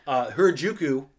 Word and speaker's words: Harajuku: That's how they say it. Harajuku neighborhood.